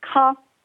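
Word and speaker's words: Caen: You pronounce it ka, kind of like K-A-H, ka. – Ka? – Oh, okay. Ka, okay. – That's just how it's pronounced, versus Khan, which is the one in the south where the film festival is.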